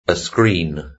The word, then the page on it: a screen: British English